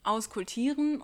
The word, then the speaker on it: auskultieren: Und ja, es heißt auskultieren und nicht, wie kultiere ich ein Herz aus, weil das von dem lateinischen Begriff auskultare kommt. Ich sage das, weil ich selber früher falsch gesagt habe. Ja, also, wie auskultiere ich ein Herz? Es gibt die Papstregel, und zwar P-A-B-S-T, also nicht wie der Papst aus Rom, sondern mit B statt P in der Mitte.